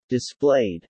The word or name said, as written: displayed